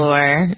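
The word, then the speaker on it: Oher: Michael Orr, is that how we pronounce his name? – Yeah, Michael Orr, and then the Tuohy family.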